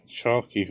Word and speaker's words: Chalke: I thought you were going to say, like, Sarah Chalke, who played Elliot, or Judy Reyes, who played Carla. – Sarah Chalk. – Sarah Chalk. Yeah, that's how it's pronounced.